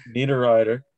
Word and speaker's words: Niederreiter: Niederreiter, thank you.